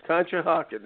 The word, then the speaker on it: Conshohocken: Conshohocken, there we go.